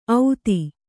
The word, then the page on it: ಔತಿ: Kannada